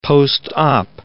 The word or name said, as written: post-op